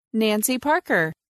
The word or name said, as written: Nancy Parker